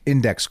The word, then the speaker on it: INDX: Him and his wife, Caitlin, of INDX coin, pronounced index coin.